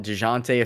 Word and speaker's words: Dejounte: Dewante Dewante Mur- I call him Dewante Murray. Everybody's calling him Dejounte. If his name's Dejounte, I guess I got to say it correctly.